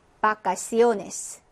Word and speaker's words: vacaciones: 예, 영어는 V 발음이 나지만 스페인어의 우베는 네, 배와 발음이 같습니다. 비읍 발음이라고 생각하시면 돼요. 그래서 어떻게 읽어주시면 될까요? vacaciones 라고 읽어주시면 됐고 verdad 하고 읽어주시면 됩니다.